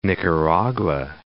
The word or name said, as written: Nicaragua